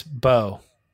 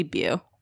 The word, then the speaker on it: Beau: B E A U is Bo. – Yeah. – It could also be Bew.